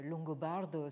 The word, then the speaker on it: Longobardo: mais Longobardo, c'est-à-dire ça s'écrit comme ça se prononce, L-O-N-G-O-B-A-R-D-O, donc qui a vécu entre 1559 et 1654.